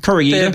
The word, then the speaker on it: Koreeda: This is the new film by the acclaimed and much-loved filmmaker Hirokazu Koreeda. Am I pronouncing that right? – Look, that's in the ballpark, I'd say. Hirokazu Koreeda. That's just, yeah. – Koreeda. – Thereabouts. – Great. – Don't say coriander.